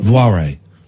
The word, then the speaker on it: Voirrey: V-O-I-R-R-E-Y. Voire. – V-O-I-R-R-E-Y. – Voire. – Voire.